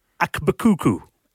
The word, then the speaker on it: Akbkuku: I think it's Akbukuku. I think it's how you say it. – A-K-B-K-U-K-U. Yeah, I think you did pretty good there.